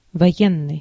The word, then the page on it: martial: /ˈmɑɹʃəl/; [mɑɹ.ʃəl]; /ˈmɑː.ʃəl/